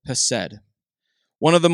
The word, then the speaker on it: chesed: The word there is chesed, and it's spelled C-H-E-S-E-D.